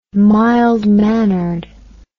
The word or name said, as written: mild mannered